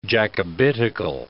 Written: Jacobitical

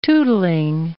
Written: tootling

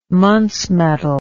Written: muntz metal

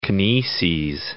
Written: kineses

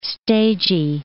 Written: stagy

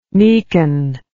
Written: meacon

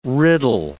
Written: riddle

